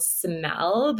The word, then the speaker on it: SYML: So there's this new cover that came out this year, and the band is called Smell, but it's spelt like S-Y-M-L.